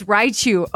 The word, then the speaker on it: Raichu: Oh, it's Raichu. Oh, well, I said it wrong in the questions then.